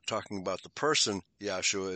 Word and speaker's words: Yahshua: But now, in this verse, the word salvation is, well, they promote it as Yeshua, Y-E-S-H-U-A. But I think it should be Yahshua, Y-A-H. S-H-U-A, that would be the correct Hebrew pronunciation.